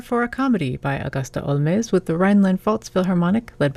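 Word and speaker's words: Holmès: Now, her father was Irish, and he might have pronounced the last name Holmes, kind of like Sherlock Holmes, but because she was born and raised in France, she pronounced it in a French manner, Augusta Olmez.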